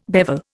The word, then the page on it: bevel: Indian